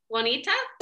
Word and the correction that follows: Oneonta: Oneonta. – I'm sorry. – It is a hard one to pronounce.